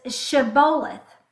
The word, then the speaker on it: Shibboleth: and then they would say Shibboleth without the sh. So it wasn't sh, it was just s.